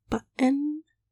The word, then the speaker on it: button: My example word I actually use normally is if you say the word button, but you don't pronounce the T's, which I often don't when I say button, so it's just button. That's a glottal stop in the middle too.